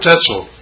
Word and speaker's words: Tetzel: It was Tetzel. – Oh, Tetzel. – Yeah. That rhymes with pretzel. – Pretzel. – Tetzel and pretzel.